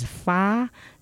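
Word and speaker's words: fa (发): Dragon rich is pronounced as fa, so it rhymes with ba, the number eight.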